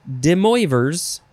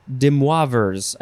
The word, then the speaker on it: De Moivre's: This is spelled capital D E capital M O I V R E apostrophe S, and that's why we hear Americans like to say De Moivre's, but that's not proper.